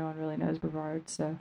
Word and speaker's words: Brevard: Brevard.